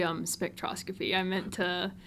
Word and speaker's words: spectroscopy: What can you share about your study of, and I'm going to butcher this word, I'm just going to do my best, petroscopy and working with the Tennessee Space Grant Consortium? – It's actually spectroscopy. It's okay, you missed a letter.